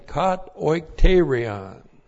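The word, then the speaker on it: kat oikterion (κατοικητήριον): And then kat oikterion. Looks like that in the Greek, K-A-T-O-I-K-T, long E-R-I-O-N.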